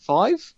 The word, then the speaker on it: Vive: And like the HTC Vive, Vive. Yep. Yeah, I did say that right. I thought it was Vibe for some reason in my brain.